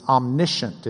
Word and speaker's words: omniscient: He's omniscient is the way we pronounce it.